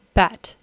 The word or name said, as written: bat